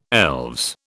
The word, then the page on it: elves: British English